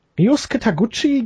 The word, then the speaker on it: Ryosuke Taguchi: Ryosuke Taguchi gegen Kenny Omega. Habe ich das jetzt richtig ausgesprochen? Ja, hast du.